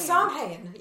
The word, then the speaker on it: Samhain: And I looked it up later and I'm like, what are these fucking words that because it's spelled Samhain. – It's Samhain, yeah.